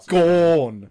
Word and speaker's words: Gawn: Yeah, it's a G-O-R-N. – Max Gawn. Yeah. G-A-W-N. – Yeah, we say words weird as well. – Gone. – Yeah, gone, mate. – Technically, it should be gone. G-O-N-E.